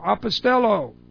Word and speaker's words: apostello: This is the word apostello, A-P-O-S-T-E-L-L, long O, apostello.